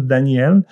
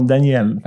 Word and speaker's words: Daniel: Il y a vraiment un J, puis ça se prononce Daniel. C'est juste comment, dans mon pays, on écrit par le son. Fait que, tu sais, il faut qu'il y ait toutes les syllabes dans le nom. Fait que nous autres, le J, il se prononce « yé ». Fait que, tu sais, ça fait un Daniel.